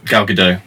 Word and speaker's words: Gal Gadot: And I can never pronounce it, Gal Gadot? – Gal Gadot.